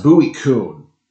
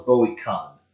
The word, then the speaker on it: Bowie Kuhn: Now, it's pronounced Bowie Kuhn. When I first encountered his name as a kid, I thought it was Bowie Kahn. But it's Bowie Kuhn.